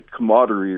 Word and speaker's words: camaraderie: Yeah, the camaraderie or whatever that fancy word is. Yeah. I can't even pronounce it. – You nailed it. You nailed it. You nailed it.